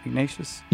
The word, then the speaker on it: Ignatius: Did I say that right? Ignatius? – You did.